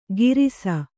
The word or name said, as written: ಗಿರಿಸ